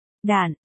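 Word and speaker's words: đản (石): Đản, là gì trước đã, từ si ở đây thời nay có nghĩa là thạch, tức đá, nhưng khi dùng làm đơn vị đo lường thì phải đọc là đản, và một đản, tương đương với 10, đầu, đu, hay xô.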